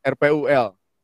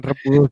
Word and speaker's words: RPUL: Kalau zaman gue kayak namanya bukan repul deh, RPUL. Iya, sama aja. – Gak bacanya jangan repul dong.